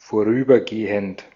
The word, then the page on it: passager: France; IPA: [pa.sa.ʒe]